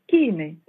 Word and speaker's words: Chine: Et les coordonnées GPS, tenez-vous bien, c'était un hameau de Canary dans le Cap Corse, un hameau qui s'appelle Kine, ça s'écrit Chine.